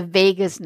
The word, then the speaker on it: vagus: So what I want to focus on here is the connection between our food, our gut, our emotions, and the vagus nerve. And this nerve is not to be confused with Las Vegas. Vagus is spelled V-A-G-U-S.